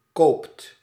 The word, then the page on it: koopt: /koːpt/